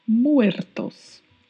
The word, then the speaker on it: muertos: Muertos. Lembrando que o R em espanhol tem sempre o vibrato, né? Então vamos pronunciar muertos. Muertos.